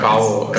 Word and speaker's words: Cavo: Yeah, Kavo. – C-A-V-O, which is in a like a glorified USB.